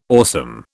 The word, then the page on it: awesome: British English